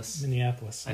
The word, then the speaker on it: Minneapolis: Am I saying that right? – Minneapolis. – I don't know why. I put an extra syllable in.